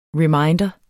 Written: reminder

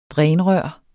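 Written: DRÆNET

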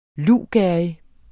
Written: luvgerrig

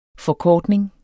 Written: forkortning

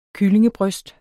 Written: kyllingebryst

